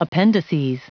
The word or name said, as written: appendices